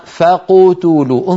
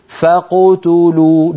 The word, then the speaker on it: فقتلوا: نأخذ مثال واحد، يعني ما يقول الواحد منا فقتلوا، انظروا إلى فمي، فقتلوا، ما يضم شفتيه مبكرا عند القاف، الضم للتاء، فإن لم تصل يا أخي إلى التاء فلا تضم، هكذا يجب أن تكون القراءة، فقتلوا، فقتلوا، الضم يكون مع تو ولا يكون مبكرا مع القاف، وإلا نكون قد نطقنا القاف فيها شيء من الضم، فقتلوا، هذا لا يصح وهو من عيوب القراءة.